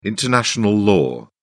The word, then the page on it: international law: British English